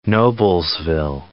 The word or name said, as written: Noblesville